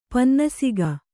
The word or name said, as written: ಪನ್ನಸಿಗ